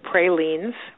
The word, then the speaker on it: pralines: Somebody very kindly sent me a bag of pralines, which she taught me how to pronounce as pralines.